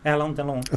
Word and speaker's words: Alain Delon: Yes, and is it Alain Delon? – Alain Delon, of course.